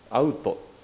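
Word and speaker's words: Alto: 先生がおっしゃってくださった教会名、日本語で書くと私がカタカナでそういうふうに書いたんですけど、アルト・ダ・ボンダーデ教会というふうに書きましたが、ブラジル語は L の音、子音の前の L の音はアイウェオのウと発音するんですね。アウト、だからアウト・ダ・ボンダーデとカタカナで書いた方が本当はいいんでしょうが、あまり馴染まないと言いますか、アルトと書いて普通に僕はアウト・ダ・ボンダーデというふうに読んでしまうんですね。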